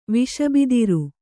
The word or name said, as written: ವಿಷಬಿದಿರು